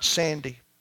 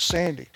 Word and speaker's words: Sandy: All I had ever known was Sandy. My family called me Sandy. My friends called me Sandy. The only name I knew was Sandy. And I had learned to spell it S-A-N-D-Y.